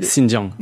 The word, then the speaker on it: Xinjiang: Je ne savais pas si je le prononce bien, c'est le Xinjiang. – Xinjiang, merci beaucoup.